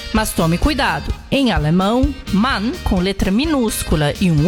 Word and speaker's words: Mann: Mann. M-A-N-N. Além de uma pronúncia diferente no inglês, destacando o A aberto na pronúncia.